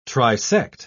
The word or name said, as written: trisect